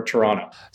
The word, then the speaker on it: Toronto: So if you're not from Toronto, you don't say the second T. It's more Toronto.